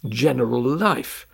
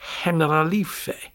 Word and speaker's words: Generalife: because it's spelt General Life, but it's the Generalife.